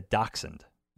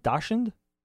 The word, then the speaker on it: dachshund: How do you pronounce this word? Why did the cowboy get a dachshund? Is it dachshund? Dachshund? – Dachshund. Oh, dachshund. That's like dachshund. A wiener dog.